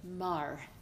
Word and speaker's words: Marr: Marr, like the planet without the S.